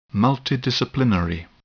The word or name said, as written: multidisciplinary